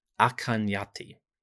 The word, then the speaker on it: Akhenaten: The princess Merit Atin is called Mayati in a foreign letter, and it's quite likely that the name Akhenaten, the pharaoh, was actually pronounced Akanyati.